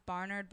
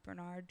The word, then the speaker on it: Bernard: It's supposed to be pronounced Barnard, but I pronounce it Bernard because it sounds better with my name.